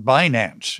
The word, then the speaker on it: Binance: Tell us about Binance. Am I pronouncing it correctly? – You are.